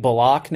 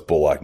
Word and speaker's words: Bullock: When you look at bringing Elfrid Payton back, bringing Bullock in the lineup, who apparently actually is pronouncing it Bullock now.